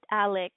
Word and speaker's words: Alex: Just Alex. A-L-E-X.